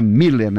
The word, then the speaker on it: Müller: Pode ser Müller, pode ser Miller, esse aqui não tem a trema, mas se escreve Müller e se pronuncia Miller, né?